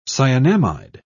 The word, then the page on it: cyanamide: British English